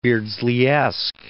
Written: Beardsleyesque

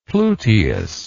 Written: pluteus

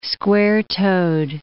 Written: square-toed

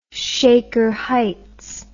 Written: Shaker Heights

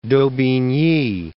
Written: Daubigny

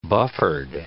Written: buffered

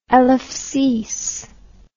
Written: Elevsis

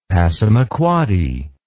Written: passamaquoddy

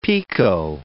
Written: picot

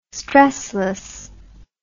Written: stressless

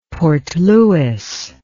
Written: Port Louis

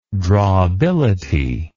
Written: drawability

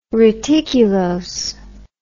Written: reticulose

